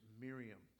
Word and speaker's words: Miriam: In Hebrew, it's the same name, but it's pronounced Miriam.